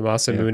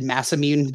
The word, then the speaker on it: Masamune: How did you pronounce it? The Masamune? – The Masamune. Yeah. That's what I say. – Is that like how it's pronounced? Because I've just always said Mass Immune, but like that sounds actually right.